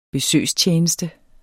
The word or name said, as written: besøgstjeneste